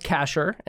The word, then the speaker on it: Kashar: Uh, last name's pronounced Kashar, and that's K A S H A R.